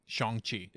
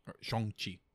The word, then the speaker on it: Shang-Chi: Because that's the right way to say it. Shang-Chi. – Yeah. – Shang-Chi.